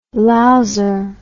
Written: louser

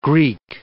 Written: Greek

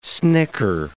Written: snicker

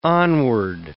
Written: onward

